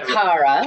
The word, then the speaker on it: Cara: Cara, not Cara. – You can say Cara.